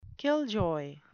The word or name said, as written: killjoy